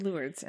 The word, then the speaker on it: Lourdes: I-L-O-U-R-D-E-S. – I know what you're talking about. – I put L-E-W-R-D to pronounce Lourdes, but people were... – Okay. – I don't know. – Lourdes. – Okay. – L-O-R-D-Z. – Right. – Is how you pronounce it.